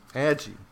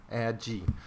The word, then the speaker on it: Agee: I think that's how it's pronounced. Agee, not a G. Agee.